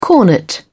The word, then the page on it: cornet: British English